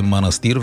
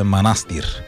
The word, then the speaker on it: Манастир: Аз в четвъртък казах, че селото е манастир. Веднага Михаил Делирадев ме контрира с абе, знаеш ли, че ударението е манастир? – Ами това е като винарска изба Драгомир.